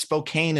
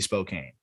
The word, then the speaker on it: Spokane: I believe you, because like, it's your job to know that, but there's an E in there. It's Spokane is a very, very fair, I feel like, pronunciation there. – I understand. – I'll wear Zach Veen being too low. I'm gonna just say it should be Spokane.